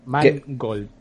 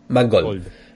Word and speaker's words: Mangold: Magold James o James Magold, no sé exactamente cómo se pronuncia. – Eh, eh Mangold. no, me equivoco, es el director Mangold. Hombre y oro, todo junto. – Mangold.